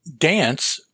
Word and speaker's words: DNCE: To dance by D-N-C-E, which I guess you pronounce dance.